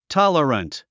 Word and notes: tolerant: tάlərənt; タララント

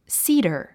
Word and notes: cedar: síːdə r; スィーダァ

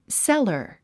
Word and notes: cellar: sélə r; セラァ

